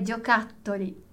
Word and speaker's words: giocattoli: Giocattoli, giocattoli. Questa è la pronuncia giusta.